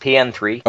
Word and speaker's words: PN03: Did you know it's technically pronounced PN3? – Oh, is it? Okay. – Yeah, it's, I can't remember where I read that, but even though it's PN03, product number 03, it's actually pronounced, if you say it out loud, it's supposed to be PN3.